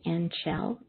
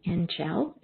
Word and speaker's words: Anchel: It just sounds like angel. As I look at it, A-N-C-H-E-L is how you spell it. So I hope it's okay if I'm saying Angel. Angel, is that better?